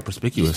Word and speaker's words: Perspicuous: Yeah, I said it right. Perspicuous. – You said it right. – I pause because I was expected to say it wrong.